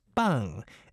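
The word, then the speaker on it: bang (빵): B-B-A-N-G, pronounced bang, as in Korean.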